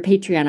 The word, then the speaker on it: Patreon: of your Patreon. I'm not saying that correctly, am I? – It sounds great to me.